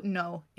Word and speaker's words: No: It is spelled N-O. And pronounce that with, way. With a little accent over it. – To tell you it's a long O, so it is how you're supposed... So it is like the word no.